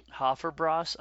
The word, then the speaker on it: Hofbräu: What is it? Hofbräu? Is that how you would pronounce it? – That sounds good to me. – Hofbräu.